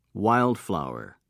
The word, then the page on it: wild flower: British English